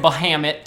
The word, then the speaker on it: Bahamut: I'm from America, and we say Bahamut.